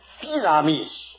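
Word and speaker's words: dynamis (δύναμις): Now, the other word, which I want to dwell on for a little while, is the word that we usually call dunamis, D-U-N-A-M-I-S. Actually, I asked a Greek the other day how he pronounced it, and he said, no, it's not dunamis, it's dynamis.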